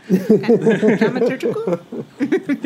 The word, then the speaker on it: dramaturgical: And yet, but so the question remains, you know, looking at it through sort of a civic dramatological. Did I say that right? Probably not. – Dramaturgical? – There you go. Civic dramaturgical lens.